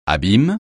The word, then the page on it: abîme: francais